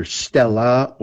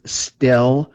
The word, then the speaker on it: stele: It's pronounced either stella or stele, or it could be stele in some people's pronouncing.